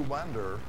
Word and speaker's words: wander: Because those who wander, those who wander, that doesn't sound right, but that's how it's spelled, W-A-N-D-E-R.